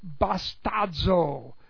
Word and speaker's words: bastazo: Present active infinitive of the verb bastazo. B-A-S-T-A-Z long O. Bastazo.